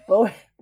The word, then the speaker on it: Bowie: Wait, how do you say it? – Bowie. – David Bowie. Oh, it is David Bowie. Shit, now I've said it, it sounds better. David Bowie. Yeah, it's David Bowie.